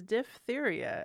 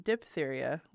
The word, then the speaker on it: diphtheria: And also, we were pronouncing that correctly. That is diphtheria, as we learned, not diphtheria, like most people.